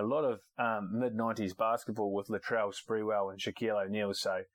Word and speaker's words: Latrell: Why can't anyone say his name? It's Latrell, as in Latrell Sprewell. What's all this lateral shit?